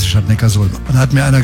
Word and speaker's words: Neckarsulm: Nee, ich glaube, das heißt Neckarsulm. – Ja, und warum? – Ja, weil es in der Datenbank steht. – Nee. Die heißt deshalb Neckarsulm, weil Ulm liegt an der Donau.